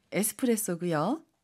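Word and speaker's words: エスプレッソ: 에스프레소, 에스프레소고요. – 밀크티, 밀크티. – 밀크티, 밀크티입니다. – 주스, 주스. – 주스, 주스인데요. 선생님 혹시 발음 팁 있을까요? – 하이, 에스프레소라고 할때 중간에 초금이 들어갑니다.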